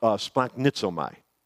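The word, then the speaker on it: splanchnizomai: It's called splanknizomai. You can practice this at home. Splanknizomai is how you say it.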